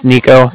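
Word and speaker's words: Niko: My name's Nico. That's N-I-K-O.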